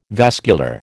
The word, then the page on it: vascular: American English